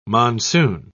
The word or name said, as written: monsoon